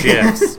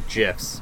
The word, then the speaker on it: GIFs: Is, my friends sending me gifts, so thank you, everyone. I'm sorry I didn't give back, but you know, again, no Pokestops. – It's GIFs, Justin. GIFs. Soft G.